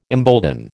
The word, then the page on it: embolden: American English